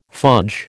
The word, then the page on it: fudge: American English